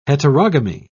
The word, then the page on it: heterogamy: British English